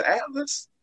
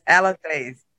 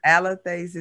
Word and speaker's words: Alethes: Is it Atlas? Did I say that right? – It's Alathase. Alathase is the Greek word for speaking the truth.